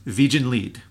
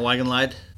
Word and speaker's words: Wiegenlied: The name of the song is Wiegenlied, which I believe is how the Germans would pronounce it, right? – Not Wiegenlied? – not Wiegenlied, no.